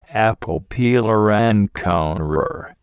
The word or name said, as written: apple peeler and corer